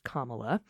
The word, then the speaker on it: Kamala: So what, he said, when a guest told him it was pronounced Kamala.